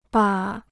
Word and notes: 把: bà

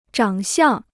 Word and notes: 长相: zhǎng xiàng

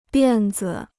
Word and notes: 辫子: biàn zi

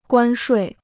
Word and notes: 关税: guān shuì

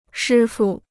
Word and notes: 师傅: shī fu

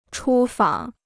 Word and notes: 出访: chū fǎng

